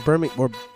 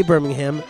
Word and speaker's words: Birmingham: That's right, Birmingham. That's the correct way to pronounce it. If you say Birmingham, they won't really know what you're talking about.